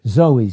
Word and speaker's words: zoe: Zoe, Z-O-E, long E, Z-O-E, Zoe, eternal life.